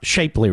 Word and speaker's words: Shapley: Sharp, Shapely, rather, is his name. Which is it, Mister Bidus? We have both. I think it's I think it's Shapely. S H A P L E Y.